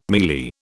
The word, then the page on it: mealie: British English